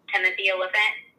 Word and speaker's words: Timothy Olyphant: Just you know, I'm a huge fan of Timothy Olyphant.